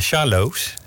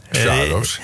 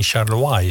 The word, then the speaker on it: Charlois: Ik kon jou weer zeggen Sjaarloos. – Sjaarloos, ja. – Ik weet dat dat zo uitgesproken wordt. Maar wat is nou de reden dat Sjaarloos geen Charlois is bijvoorbeeld?